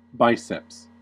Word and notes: biceps: American English

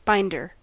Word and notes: binder: American English

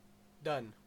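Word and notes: done: Canadian English

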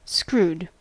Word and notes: screwed: American English

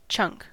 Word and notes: chunk: American English

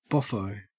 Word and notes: boffo: Australian English

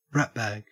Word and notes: ratbag: Australian English

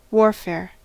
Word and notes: warfare: American English

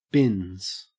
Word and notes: bins: Australian English

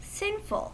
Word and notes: sinful: American English

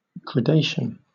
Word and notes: gradation: British English

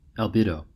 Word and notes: albedo: American English

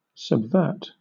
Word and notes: subvert: British English

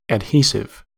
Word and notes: adhesive: American English

